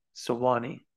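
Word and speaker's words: Suwanee: It's really Sewanee.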